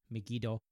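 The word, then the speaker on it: Megido: Megiddo. It's romanized as Megid, but Megiddo is how it's said in Japanese.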